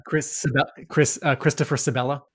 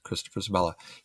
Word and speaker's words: Christopher Sebela: Chris Sebla. Sebla? Sebla? I'm probably mispronouncing. – Christopher Sebela? – Yes, Christopher Sebela.